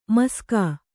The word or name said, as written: ಮಾಷಕ